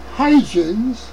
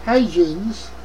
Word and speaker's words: Huygens: Huygens, how you say it in English, remember New York City and Brooklyn have a very deep Dutch heritage. And so when we pronounce Dutch words, we're not claiming to be the same as they're pronounced in Holland, but it's a regional way of pronouncing those names. Anyway, Huygens is H-U-Y Yankee G-E-N-S, and however you want to pronounce it, no, you're on your own.